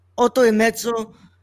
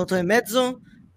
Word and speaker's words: Otto e Mezzo: 8.5, Otto e Mezzo, pronounced, what's it called, Otto e Mezzo,